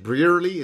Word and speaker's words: Brearley: Give us, before we get into the crux of the actual contents of the letter, so is it Brearley? Is that how you pronounce it? – That's right, exactly.